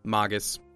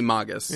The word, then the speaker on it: Magus: Magus. – Yes. I win. – It's definitely Magus.